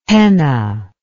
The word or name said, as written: penna